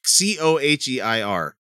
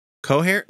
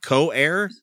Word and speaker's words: coheir: C-O-H-E-I-R. Co-hair? That's how you say that, right? – Co-chair? – It's like hair, like, and like an heir apparent or like an heir to the throne, like co-heir. – Spell it again. – C-O-H-E-I-R. – Oh. – Co-heir. – Co-heir. You leave the H out.